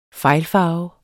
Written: fejlfarve

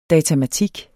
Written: datamatik

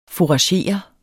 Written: FOURAGERE

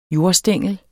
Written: jordstængel

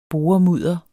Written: boremudder

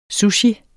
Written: sushi

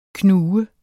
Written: knug

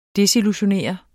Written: desillusionere

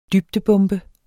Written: dybdebombe

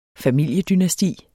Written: familiedynasti

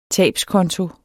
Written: tabskonto

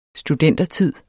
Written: studentertid

